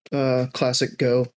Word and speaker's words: ClassicGho: Just YouTube, ClassicGho, GHO.